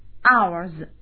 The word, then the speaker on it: ours: 接下来我们看一下啊， our 表示我们的，那么 our 的名词性物主代词是 ours，也是在 our 的后面加上一个 letter s，字母 s，那么发音的时候也是 ours 啊，要浊音。